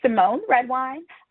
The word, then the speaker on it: Symone Redwine: Yeah, it's Symone Redwine. I spell it S-Y-M-O-N-E, Redwine. Also, Attorney Symone, S-Y-M-O-N-E.